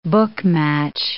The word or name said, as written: book-match